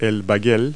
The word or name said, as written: bagel